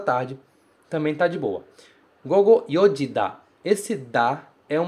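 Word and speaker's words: gogo (午後): Ele se pronuncia gogo, gogo.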